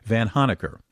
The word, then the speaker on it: Vanhoenacker: Vanhoenacker.